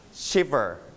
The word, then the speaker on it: shiver: In English, we call it shiver. Shiver. Listen to the audio for two times. The first time. – Shiver. – One more time. – Shiver. – You stress on the first syllable, shiver.